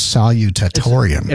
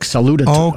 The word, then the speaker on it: salutatorian: She was salutatorian. – Yeah. – I can never say that word. Actually, the way it's spelled is salutatorian. – It's almost like a salute, like salutatorian.